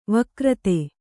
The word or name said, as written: ವಕ್ರಿತ